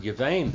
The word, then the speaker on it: yuvain: And then yuvain, this word yuvain there, now it's got a bath in it, but the bath there is pronounced with a V, not a B, because it has no dogish in the middle of it.